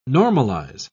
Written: normalize

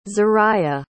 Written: zariah

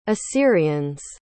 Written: assyrians

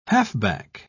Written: halfback

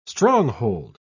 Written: stronghold

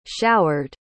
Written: showered

